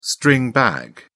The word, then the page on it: string bag: British English